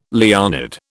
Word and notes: Leonid: British English

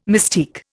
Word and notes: mystique: Indian

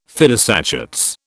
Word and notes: fidus Achates: British English

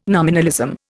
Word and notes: nominalism: Indian

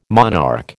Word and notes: monarch: American English

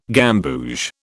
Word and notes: gamboge: British English

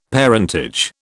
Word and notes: parentage: British English

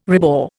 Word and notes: rebore: Indian

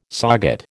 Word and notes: SOGAT: American English

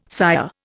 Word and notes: sire: Indian

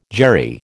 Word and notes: Jerry: American English

